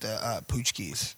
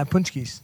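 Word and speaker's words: pączki: The lady at Kroger said they're pronounced poochkies. – Yeah, poochkies. – You put an N in there. There's no N in it.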